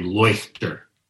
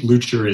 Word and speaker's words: Leuchter: Lucher is how he seems to say it in the film, but then when Nazis are saying his name, they say Leuchter, which is more German. But I think Lucher is the sort of general Americanized pronunciation.